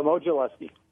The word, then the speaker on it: Modzelewski: Is it Modzelewski or Modzelewski? – Modzelewski. – Yeah, sometimes the W is pronounced like that.